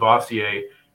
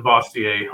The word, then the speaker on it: Bastiat: every libertarian loves to pronounce Bastiat a little bit differently. I like that one because it's easier to spell and sounds like it to dumb Americans.